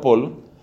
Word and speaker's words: Paul: Escriba el nombre de este convertido famoso de principios del siglo XX, Paul, escrito Paul, se pronunciaba Paul, Paul Claudel, pronunciado Claudel, Paul Claudel.